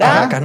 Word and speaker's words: Caracas: Caracas no lleva acento. Sí, yo no lleva acento, pero, la, sílaba tónica es en, la segunda.